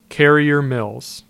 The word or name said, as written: Carriers Mill